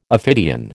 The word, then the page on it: ophidian: American English